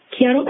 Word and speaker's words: hiatal: Hiatal hernia? – Hiatal? – Yeah, I guess I'm not sure how to pronounce it properly. – Yeah. Yeah, hiatal hernia.